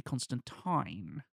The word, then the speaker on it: Constantine: The comic book character is very notably Constantine. – Mm-hmm. He specifically clarifies his pronunciation. Yeah. – Tine like wine.